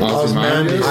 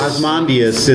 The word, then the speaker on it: Ozymandias: Ozymandias. – Ozymandias in the Watchmen television series.